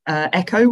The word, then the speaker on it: ECCO: it was a shoe company called Echo, which is spelled ECCO.